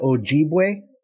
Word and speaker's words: Ojibwe: How do you say that? Ojibwe? – Yeah.